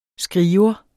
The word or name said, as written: skriver